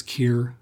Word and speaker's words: Kier: Hello, my name is Kier Graf. As you can hear, my first name rhymes with ear, and as you can see, it is one of the many confounding exceptions to the rule I before E except after C.